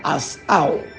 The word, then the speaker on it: asah: So now the Hebrew word for create is bara, b-a-r-a. Then the Hebrew word for make is asau, asau, but it's spelled a-s-a-h.